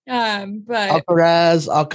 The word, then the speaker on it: Alcaraz: What is Carlos Alcaraz swinging? I'm trying my best to pronounce correctly. – Alcaraz.